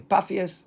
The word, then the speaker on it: Papias: Paphias, with a shva under the pay, second pay. – Paphias. – Kamat shva, like two dots, right?